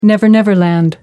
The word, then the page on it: never-never land: American English